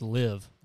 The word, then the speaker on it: LIV: I thought it was an acronym, LIV. They said it's pronounced Live. Yeah, it's the Live Tour.